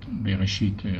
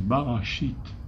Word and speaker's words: Bereshit: Bereshit à la synagogue et se prononce Bereshit. Ça, c'est quoi? Ça comporte 6 lettres. Mais Bereshit ne peut être prononcé que si on met des points voyelles qui le font prononcer Bereshit.